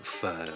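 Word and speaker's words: phở: Donc, on va prononcer pho. Donc, c'est pas la même chose que si on disait pho ou pho ou pho, selon différents tons.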